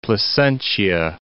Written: Placentia

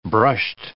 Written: brushed

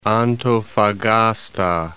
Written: Antofagasta

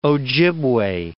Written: Ojibway

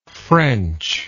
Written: frenchness

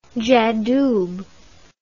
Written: j'adoube

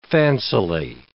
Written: fancily

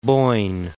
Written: Boyne

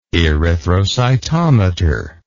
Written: erythrocytometry

